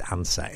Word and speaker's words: Ansah: No, I think it's Ansa, A-N-S-A-H.